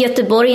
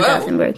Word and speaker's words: Göteborg: Did you know that it's pronounced Göteborg in Swedish? It's not Gothenburg.